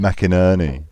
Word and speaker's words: McInerney: I thought Tim McInerney was brilliant. – How do you say his name? – McInerney. – Is that right? – Yeah.